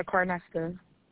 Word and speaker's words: Carnesta: Yeah, Carnesta. – Could you spell that for me, please, stop, baby? – C-A-R.